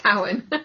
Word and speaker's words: Samhain: Because that's how it's spelled. It's spelled like you would say, call it Samhain, but it's not. It's Samhain.